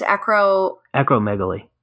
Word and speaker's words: acromegaly: Acromegaly.